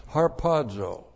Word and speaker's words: harpazo: There is no word rapture, but this is the word harpazo. Looks like that in the Greek, H-A-R-P-A-Z, long O, harpazo.